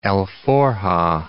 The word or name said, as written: alforja